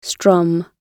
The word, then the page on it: strum: British English